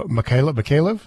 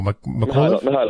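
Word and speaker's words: Mykolaiv: Mykhailov? Did I say that right? No, Mykhailov.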